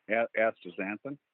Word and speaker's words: astaxanthin: And in one of your products, you have added axanthinum. I'm not sure if I said it right. – Astaxanthin? – Yeah.